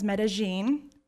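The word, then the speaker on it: Medellín: We went down to Medellin, also known as Medellin. Yeah, I've learned that pronunciation.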